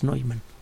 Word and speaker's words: Neumann: Se escribe Neumann, la pronunciación es Neumann.